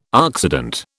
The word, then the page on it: oxidant: British English